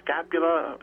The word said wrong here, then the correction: scapular: Is that what you say? – Scapular. Uh-huh.